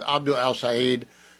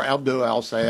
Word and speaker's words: El-Sayed: NBC News caught up with Abdul El-Sayed, or Abdul El-Sayed, I don't know how to say it. People say it both ways.